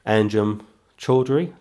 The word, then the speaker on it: Anjem Choudary: Anjem Choudary, that's how you pronounce his name, and people such as him.